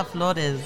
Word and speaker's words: Flores: The way I pronounce my name when I meet people, I'm like, Hello, my name is Felicia Flores. I need to stop Flores. – Doing that. I need to be like, hola, me llamo Felicia Francisca Flores.